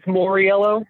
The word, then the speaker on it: Mauriello: And it's Mauriello. You didn't butcher it.